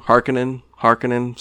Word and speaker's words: Harkonnen: Harkonnen, sorry, correct pronunciation.